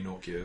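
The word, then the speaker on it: Nortje: Naughty? – Yeah, people have been saying Nokia. There's no K in his name, so I don't know where it comes from, but apparently Nokia is what it's...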